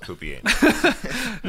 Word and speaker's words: Pupienus: It's P-U-P-I-E-N-U-S. I didn't know it would be pronounced Poopy Anus.